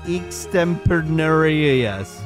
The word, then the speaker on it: extemporaneous: Did you get the pronunciation guide? Did you try that out? – Yeah, it says extemporaneous.